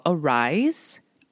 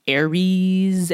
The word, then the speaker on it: Aeriz: And Arise, I literally just learned how to pronounce this fucking pronounce the name of this company like a few days ago because I kept calling it looks strange. I kept calling it like Aries and like Arises or some shit. It's spelled A-E-R-I-Z.